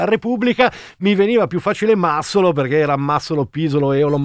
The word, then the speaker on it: Massolo: Nel frattempo mi informa la Ghidini, che arriverà dopo con Prisma al termine del giorno delle locuste, che non si dice Massolo ma Massolo. – Massolo.